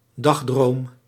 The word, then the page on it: dagdroom: dag·droom